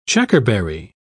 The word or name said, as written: checkerberry